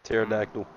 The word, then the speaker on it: pterodactyl: Pterodactyl. – Yeah, what the fuck? The fact that there's a P in front of that is crazy. That is some shit.